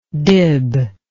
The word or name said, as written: dib